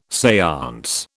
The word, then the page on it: seance: British English